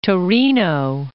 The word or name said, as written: Torino